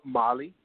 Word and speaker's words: Mali: The three great empires, I heard you mention Ghana, and then it sounded like you said Mali, Baja. – No, Mali.